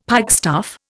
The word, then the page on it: pikestaff: Indian